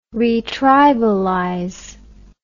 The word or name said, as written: retribalize